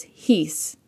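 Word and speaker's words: his (εἷς): One in Greek is his, spelled epsilon iota sigma, with an accent mark over the iota to indicate that it has to be pronounced with an H sound, his.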